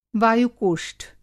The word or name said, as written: वायु-कोष्ठ